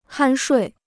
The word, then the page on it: 酣睡: hān shuì